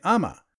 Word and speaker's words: Umma: And the mom and the dad in that show a lot of times are called Appa and Amma. So even though phonetically in English it's spelled U-M-M-A, it's not pronounced that way. So thank you, Canadian sitcoms, for telling me how to pronounce a Korean word.